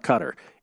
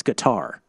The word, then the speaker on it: Qatar: So for the whole FIFA World Cup in Qatar, by the way, we got a tweet that says, Gil, you're pronouncing it wrong. It's not Qatar. It's Qatar.